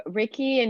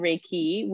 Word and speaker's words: Reiki: Yeah, so Reiki, and you can pronounce it a bunch of different ways, but sometimes I pair it together as the two parts of the word. So Rei, meaning universal, and Ki, meaning energy.